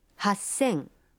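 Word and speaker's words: Хассен (八千): Но и здесь произношение меняется. 3000 будет звучать как... – Санзен. – 8000. – Хассен.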